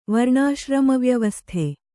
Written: ವರ್ಣಾಶ್ರಮವ್ಯವಸ್ಥೆ

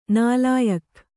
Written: ನಾಲಾಯಖ್

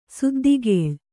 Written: ಸುದ್ದಿಗೇಳ್

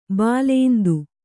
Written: ಬಾಲೇಂದು

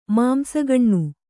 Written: ಮಾಂಸಗಣ್ಣು

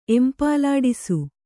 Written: ಎಂಪಲಾಡಿಸು

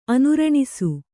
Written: ಅನುರಣಿಸು

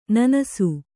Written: ನೆನಸು

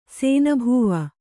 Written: ಸೇನಭೋವ

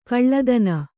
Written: ಕುಲ್ಲತನ

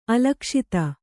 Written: ಅಲಕ್ಷಿತ

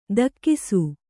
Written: ದಕ್ಕಿಸು

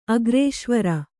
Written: ಅಗ್ರೇಶ್ವರ

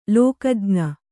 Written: ಲೋಕಜ್ಞ

